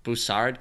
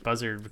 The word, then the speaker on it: Bussard: I always pronounce it Buzzard. – Bussard. – Bussard? – Bussard. – Okay. – It's Bussard. Yeah, take it from the Canadian. He knows how to pronounce things.